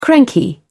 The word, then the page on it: cranky: British English